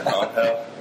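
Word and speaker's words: Ángel: They've got Juan Agudelo, they've got Juan Pablo Angel, who's... – On hell. – Red Bulls. What's that? – On hell. – On hell. – Yeah. It's a Spanish pronunciation of Angel.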